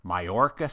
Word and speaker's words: Mallorca: But this time, the good people at ExoCAD are holding their amazing conference that they call ExoCAD Insights in Mallorca, Spain.